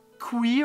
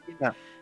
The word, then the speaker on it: Quina: Bah, tu vois, Kui, le, celui qui aime bien les grenouilles, j'ai jamais réussi à le prononcer. – Quina.